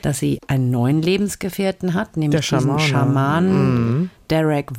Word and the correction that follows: Durek: Verrett. Heißt er so? Ja, also schreibt sich Durek. – Durek Verrett wird da, glaube ich, ausgesprochen.